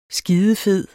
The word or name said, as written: skidefed